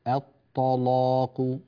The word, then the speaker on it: الطلاق: الطلاق فخم اللام أكثر من ذلك. الطلاق.